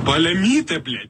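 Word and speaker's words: паляниця: Palanitsia. Palanitsia.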